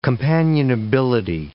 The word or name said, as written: companionability